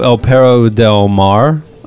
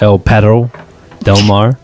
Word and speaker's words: El Perro del Mar: You're listening to El Perro del Mar. I butchered that. El Perro del Mar.